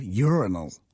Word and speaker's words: urinal: When you analyse a urinal... – Yeah. – Or, if you're in America, urinal. That's how they... Don't look at me like that, Adam. That's how they say it.